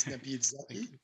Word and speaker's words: Nabil Zaki: My name is Nabil Zaki.